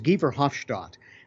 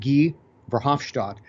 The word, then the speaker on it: Guy Verhofstadt: His name is Guy Verhofstadt. It looks as though his name is Guy for us, but in Europe they would pronounce it Guy Verhofstadt.